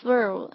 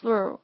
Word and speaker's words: through: 这个短语说起来有点咬嘴，是不是？因为 through, thick, thin 都是 th 字母组合开头的，发这个音的时候的确比较困难。大家要先把这个你的舌尖放在上齿跟下唇的这个中间哈， through, through 发这个音的时候，先把舌头放出来，然后再把它收回来，才是一个正确的一个发音。